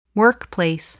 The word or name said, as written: workplace